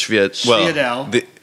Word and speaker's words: sfogliatelle: Schviedel. It's spelled sfogliatelle. – Yes. – But I guess in New York we call it Schviedel. – Yes. – It's like mozzarella. – Italian Southern. That's the Italian-American / Southern Italian pronunciation.